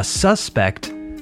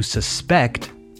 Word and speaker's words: suspect: A suspect, with the stress on the first syllable, is a person who is possibly guilty, culpable, of committing a crime, un sospechoso. The suspect was seen running down 33rd Street, for example. Now, to suspect, stress on the final syllable, means to have an idea or impression about a certain truth, una verdad and usually without proof, sin pruebas, sospechar in Spanish.